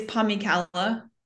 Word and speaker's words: Pomykala: It's Pomykala.